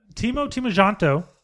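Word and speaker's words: Timo Tjahjanto: No. Timo Timojanto. – That's right.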